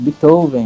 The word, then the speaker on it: Beethoven: É o Beethoven, é assim que eu pronuncio, não? – Exatamente, Beethoven.